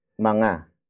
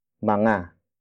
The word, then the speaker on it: mga: Up next is manga. Manga. Manga. Manga is spelled as M-G-A.